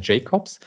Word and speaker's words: Jacobs: wird eigentlich die Aussprache Jakobs äh, äh, hier von, von, von uns verwendet, aber weil wir eine internationale Uni sind, ähm, auch gerne Jacobs.